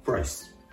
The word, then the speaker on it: price: ですが、発音のポイントはやはりこのプライスの R の発音。英語の R の発音は、発音する直前小さくうというような形で、プライス、プライスと発音してあげるところがポイント。